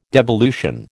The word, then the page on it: devolution: American English